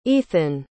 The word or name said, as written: Eithan